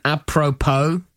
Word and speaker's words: apropos: Five words with hidden S words, silent S words. Isle. – Yes. – Island. – Yeah. – Debris. – Yeah. – Apropos. – Oh, nice. – And one I think you really like, bourgeois.